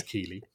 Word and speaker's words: Keighley: Jeff Keighley, sorry, I was saying in our green room that I want to pronounce his name like the town in Yorkshire, but it is Keighley.